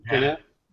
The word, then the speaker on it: Gannett: Republic Media, which is a Gannett. Am I saying it right? – Gannett.